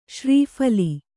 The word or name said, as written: ಶ್ರೀಫಲಿ